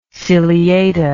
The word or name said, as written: ciliata